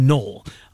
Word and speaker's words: Noel: Noel is the French pronunciation. Jalen Noel. We know what he can do.